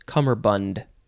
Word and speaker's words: cummerbund: If you want to say it properly, it's cummerbund. I think we should probably post a picture of a fun cummerbund.